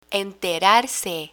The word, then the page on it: enterarse: Spanish